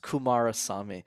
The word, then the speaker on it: Kumarasamy: And the last name is pronounced Kumarasamy.